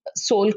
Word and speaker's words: Sol: I make Sol curry is one of my palate cleansers. Now, those who don't know Sol curry is basically, it's not S-O-U-L, it's S-O-L. That's how it's pronounced, but it is Sol, S-O-U-L.